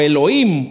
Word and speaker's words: Elohim: lo que sucedió es de que el hebreo se llama Elohim, por ejemplo, o Elohim, porque la H no se pronuncia.